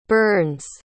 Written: burns

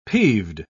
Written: peeved

